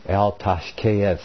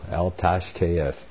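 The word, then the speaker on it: Al Tashcheth: Psalm 58, to the chief musician, El Tashkeith. That's phonetically pronounced correct in Hebrew. El Tashkeith.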